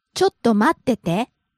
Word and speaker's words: ちょっと待ってて: ちょっと待ってて. – Okay, why do we have two te's? – I think this sentence is originally, ちょっと待っていてください. – And in casual Japanese, the ite would become just te.